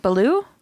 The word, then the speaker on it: Belew: He worked with frickin' Adrian Belew. I don't know if I'm saying that right. – Belew? – Adrian Belew. Mm-hmm. Belew, like Belew. Jungle Book.